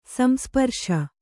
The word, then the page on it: ಸಂಸ್ಪರ್ಶೆ: samsparśe